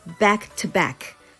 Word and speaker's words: back to back: はい、えー、次、えー、 to これは back to back というよりも back to back back to back t t ですね to ではなくて t t っていう発音にする